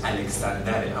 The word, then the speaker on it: الکسندر: میگه بعد از پادشاهی دوش، یعنی پادشاهی بد اسکندر الکسندر، تو پهلوی نمیگه اسکندر، اسکندر تلفظ عربی، تلفظ پهلویش الکسندره، اما الکساندر را خوب نزدیکتر.